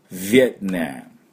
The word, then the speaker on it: Vietnam: V の発音ね、これが大事です。 Vietnam。